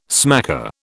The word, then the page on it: smacker: British English